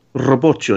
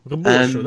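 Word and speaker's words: Rebocho: You said it correctly, actually. That's how you have to pronounce it. Robocho. – It comes, comes off his, his hand. Robocho. Robocho. That's how you have to say it in Portuguese.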